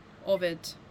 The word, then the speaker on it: Ovid: Actually, her heir, Ovid. – What was that? Sorry, spell that please. – O-V-I-D. Like the poet.